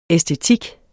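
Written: æstetik

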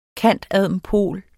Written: cand.adm.pol.

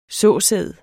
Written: såsæd

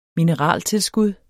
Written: mineraltilskud

